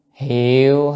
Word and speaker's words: hiệu: Là 8 La Mã chấm 8 là chú thích đại kinh hiệu hội bổn giả. À, chữ này đọc là hiệu nha.